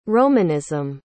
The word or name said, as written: romanism